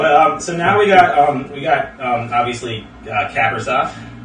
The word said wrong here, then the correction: Kaprizov: Kaprizov, right? – Kaprizov. – That's right, you assholes.